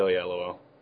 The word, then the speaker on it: Oyelowo: David Oyelowo.